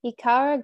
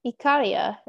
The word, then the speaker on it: Ikaria: Ikaria, Greece. Sorry, Ikaria is probably how it's pronounced.